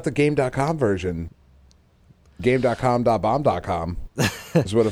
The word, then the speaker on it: Game.com: This thing, I'm pretty sure it's pronounced Gamecom, because I feel like that's how they said it in the commercials, which is silly, because it's not written that way.